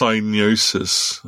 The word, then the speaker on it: Psygnosis: It's actually pronounced Psygnosis.